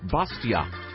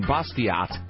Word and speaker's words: Bastiat: So the quote I was paraphrasing yesterday about education comes from Frederic Bastiat. Ben Libertarian reminds me that because he is French, the last T in Bastiat is silent.